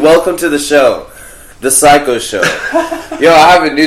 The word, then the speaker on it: Pshow: Welcome to the Psycho Show. – Psycho and show with the silent P.